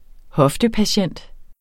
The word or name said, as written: hoftepatient